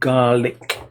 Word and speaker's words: Gaelic: In Scotland, this is from Andrew Conway, Scottish Gaelic is usually just called Gaelic, pronounced Garlic. I see. use it phonemically. An Irish Gaelic is pronounced Gaelic, but often prefixed with the word Irish.